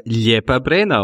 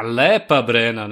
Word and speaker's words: Lepa Brena: Äh, Lepa Brena auf Bosnisch. Na, Lepa Brena natürlich. Du hast es nämlich kroatisch ausgesprochen anstatt serbisch.